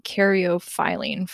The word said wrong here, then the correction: caryophyllene: for a long time. – Say it again. – Caryophyllene.